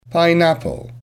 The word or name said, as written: pineapple